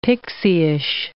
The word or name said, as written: pixieish